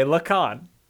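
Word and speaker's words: Lekan: It's L-E-K-A-N. – Okay, Lacan.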